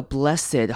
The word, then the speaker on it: blessed: 注意到这个 blessed 后面的 ED 要念成 I，短母音 I 加 D 的声音 blessed heavenly blessed beauty。